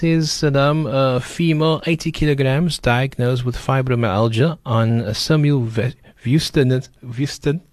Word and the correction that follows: simvastatin: Is that the correct pronunciation? – I don't know if it's Simvastatin. – Simvastatin, yeah. Yes.